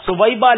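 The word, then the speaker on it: ثویبہ: ولادت مصطفیٰ صلی اللہ علیہ وسلم کی خوشی میں اس نے اپنی لونڈی سویبیہ کو آزاد کیا تھا, اس سبب سے اس کے عذاب میں تخفیف کی جاتی. – اس کا صحیح تلفظ ہے سویبا لونڈی کو اس نے آزاد کیا.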